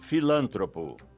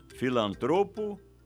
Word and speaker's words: filantropo: Como você pronuncia filantropo ou filântropo? Ibero ou íbero? Avaro ou ávaro? Todas essas palavras são paroxítonas e assim devem ser pronunciadas filantropo, ibero, avaro.